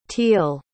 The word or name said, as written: teel